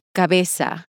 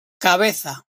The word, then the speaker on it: cabeza: The Z is pronounced like the TH in the English word think. So instead of cabeza, you'll hear cabeza.